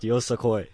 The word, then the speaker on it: Yosakoi: Yosaki dance team. At least that's how I am told you pronounce it. No? Yes? – It's pronounced Yosakoi.